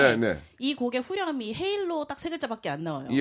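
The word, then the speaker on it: Halo: 저는 헤일로.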